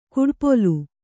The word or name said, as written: ಕಡುಪೊಲ್ಲ